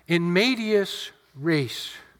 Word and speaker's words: in medias res: It's pronounced in medias res. It means into the midst of things.